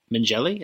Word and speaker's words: Mengele: talking Josef Mengele. Is that how you pronounce his name? – We'll say it is.